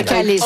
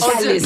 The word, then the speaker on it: calice: On dit un calice. – Calice. – On dit un calice. – Bon. – C'est un calice. – C'est un naturel qui ressort. – J'ai pété la prononciation.